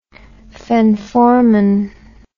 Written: phenformin

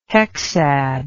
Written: hexad

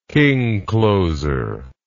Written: king closer

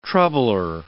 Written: troubler

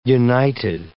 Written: united

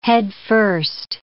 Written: headfirst

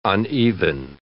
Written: uneven

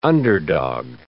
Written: underdog

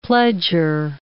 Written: pledgor

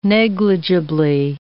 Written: negligibly

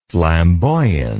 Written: flamboyantly

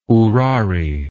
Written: ourari

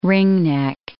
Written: ringneck

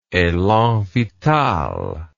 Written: elan vital

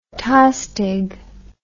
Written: Tostig

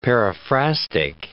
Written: periphrastic

